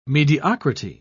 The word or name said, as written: mediocrity